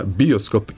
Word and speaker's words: bioskop: Bioskop i bioskop, to je kao mikroskop i mikroskop, i palačinka i palačinka.